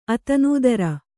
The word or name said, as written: ಅತಿನಿದ್ರೆ